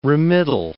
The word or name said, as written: remittal